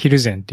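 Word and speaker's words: 蒜山: とか思っちゃうんですけども、これヒルゼンって読む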